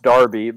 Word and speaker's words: Derby: North London Derby, pronounced Darby.